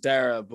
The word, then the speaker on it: Daragh: So my name is Daragh, but usually it's spelled with two R's, but my name is spelled with one R.